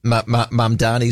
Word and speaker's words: Mamdani: The New York mayor, Mandami, and now I haven't said his name out loud before, so it might have an extra syllable in there. I should get it right. Let me just Google that up. Mamdani, Zoran Mamdani.